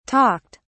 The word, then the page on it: talked: British English